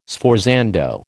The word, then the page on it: sforzando: American English